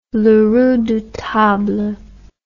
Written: Le Redoutable

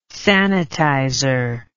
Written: sanitizer

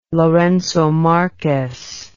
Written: lourenco marques

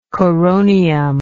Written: coronium

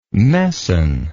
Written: messin

